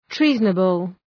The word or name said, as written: treasonable